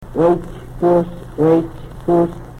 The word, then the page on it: Wechpus: Yurok